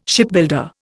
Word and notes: Shipbuilder: Indian

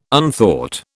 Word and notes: unthought: British English

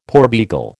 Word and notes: porbeagle: American English

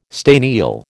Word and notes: staniel: American English

